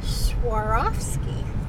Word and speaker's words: Swarovski: Oh, Swarovski. – Swarovski. Swarovski. – Swarovski. – Swarovski.